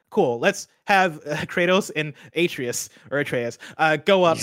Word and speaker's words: Atreus: I do know his name is pronounced Atreus.